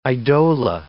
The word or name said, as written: eidola